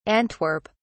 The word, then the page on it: antwerp: British English